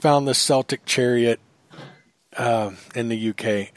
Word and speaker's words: Celtic: Celtic. Okay. Looks like it's a C, but it sounds like a K.